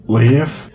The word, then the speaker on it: Lief: Life is spelled L-I-E-F. – Leaf. Probably easier just to say Life.